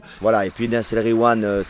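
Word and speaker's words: Nass El Ghiwane: Nas El Riwan. – Nas El Riwan, voilà. Et quand il y a GH, tu dis Re. Tu fais comme si c'était un R.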